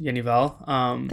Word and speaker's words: Jenny Hval: Welcome to the Fader podcast, Jenny Hval. – Thank you. – Pronouncing that right, correct? – You are. Very good.